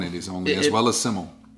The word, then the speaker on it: Smyl: S-Y-M-L. This is called Smile. – Simmel. Simmel is the Welsh word for simple. – Well, makes sense. – I had to reach out to the record label to find out how to pronounce S-M-Y-L. – Because I thought it was Smile, you know? – Yep.